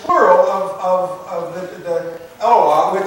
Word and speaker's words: Eloah: Okay, it's plural for the word Eloah. Aloha. Almost like Aloha, you know, a Hawaiian thing.